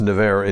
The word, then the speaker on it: Nevers: It's pronounced Nevers in France, but it's spelled like Nevers.